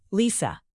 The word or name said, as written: lisa